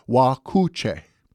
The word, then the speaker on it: Wakuche: The Ho-Chunk tribe called that name phonetically similar to Okooch, pronouncing it Wakuche.